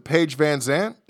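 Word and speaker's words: Paige VanZant: Lambert brings up Jericho's comments, and I may have said her name wrong, but is it Paige Van Zandt? – Yep, that's exactly how you say it.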